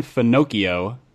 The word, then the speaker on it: Finocchio: There's a guy from Hillsong named Pastor Nathan Finocchio. Rhymes with Pinocchio.